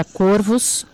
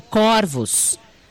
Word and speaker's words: corvos: Estranhei que a palavra corvos, foi pronunciada com o O fechado. Olha lá, igual eu. Pesquisei e descobri que, diferentemente do singular, no plural, a palavra corvos é pronunciada com o O aberto, corvos.